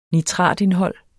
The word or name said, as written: nitratindhold